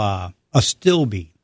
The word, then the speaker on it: astilbe: And last here is what we call a stilbe, or astilbe, whatever way you want to pronounce it.